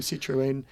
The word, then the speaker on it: Citroën: So kudos to them, Citroën. – And then kudos to you to do the right pronunciation.